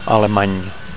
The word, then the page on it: alemany: Catalan